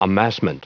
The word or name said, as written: amassment